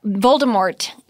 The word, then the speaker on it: Voldemort: But Voldemort doesn't you don't pronounce the T apparently.